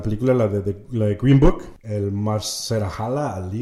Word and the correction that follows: Mahershala Ali: Mahershala Ali.